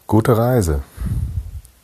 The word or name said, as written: gute reise